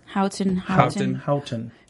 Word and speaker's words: Houghton: Houghton. – H-A-U-G-H-T-O-N, is that it? – Yeah. – H-O-U. – Houghton. Okay. – G-H-T-O-N. – Kind of like Houston, Houghton. – Houghton.